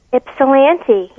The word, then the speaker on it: Ypsilanti: This is actually a town called Ysipelanti. Is that how you say it? – Ypsilanti, yeah.